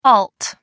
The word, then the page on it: alt: American English